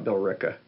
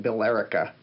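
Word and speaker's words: Billerica: Well, and forget about Bill Ricka. – Oh God. Yeah. – Is that it? – Well, you might look at it and call it Bill Erica. – Oh, okay. Right, right. It's just dropping a syllable.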